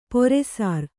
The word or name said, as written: ಪೊರೆಸಾರ್